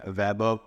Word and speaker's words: Vaibhav: You find me on YouTube with my name, Vaibhav Ali, which I'm sure most of them over here will be a... – Spell that for me. It is Vaibhav space Ali, A-L-I.